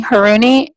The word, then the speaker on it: Haruni: It's my name is spelled Nadine, N-A-D-I-N-E, Haruni, H-A-R-U-N-I.